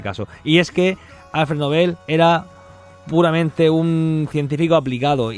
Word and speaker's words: Nobel: Que tenemos premio Nobel de la paz y de literatura a cosas que se han metido, economía, se ha metido después, y ya Nobel, que se pronuncia Nobel, pues no tiene nada que decir sobre esto.